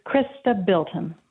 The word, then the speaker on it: Chrysta Bilton: Is it Bilton or Bilchin? – It's Chrysta Bilton.